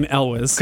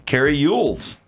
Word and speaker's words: Elwes: He pronounces his name Elwes. – Okay, and we have been raised to pronounce it Cary Elwes.